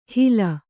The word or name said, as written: ಹೀಲ